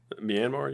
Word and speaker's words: Myanmar: Myanmar, you mean? – I can't pronounce it.